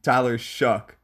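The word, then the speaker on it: Shough: It is pronounced Tyler Shue. No, it's not. Wait. It is pronounced Tyler Shuck, not Tyler Shue.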